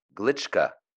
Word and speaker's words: Glitschka: I mean, it's we've been friends for all these years, and I think it's Glischka. – Yes. – It's just the southern pronunciation.